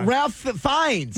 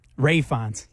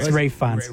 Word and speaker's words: Ralph Fiennes: Ray Fiennes. – Oh, no, never mind. Sorry. Ralph, it's spelled like Ralph, though. – It is. – Yes, it is. – What is it called? – It's Ray Fiennes.